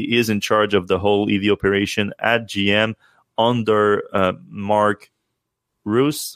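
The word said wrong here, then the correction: Reuss: How do you pronounce his last name? – Royce, yeah.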